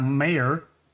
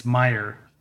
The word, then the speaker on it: Meyer: He had a character named Martin in a book that Scott Mayer Meyer I got to pronounce it. I'm like, which way is it? It's Meyer, Scott Meyer, uh, who's the author, wrote, uh, who we also had on as a guest.